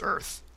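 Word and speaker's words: Oerth: is it Orith? I always pronounce it wrong. – It's pronounced Earth. Yeah. – Oh, is it really? – Yeah. It's cool. Pronounced just like Earth.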